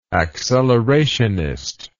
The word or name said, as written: accelerationist